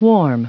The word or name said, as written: warm